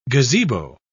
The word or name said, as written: gazebo